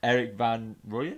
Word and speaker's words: Van Rooyen: Eric Van Rooyen, is that how you say it? – Yeah, that's good.